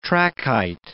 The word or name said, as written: trachyte